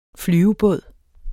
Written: flyvebåd

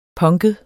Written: punket

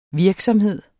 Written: virksomhed

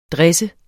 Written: dresse

